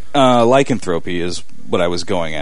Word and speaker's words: lycanthropy: It's actually lycanthropy. – Lycanthropy is what I was going after. – It's pronounced lycanthropy. – It's lycanthropy. When in doubt, here's the rule you should follow. When in doubt, put the accent on the semi-penultimate syllable. Lycanthropy.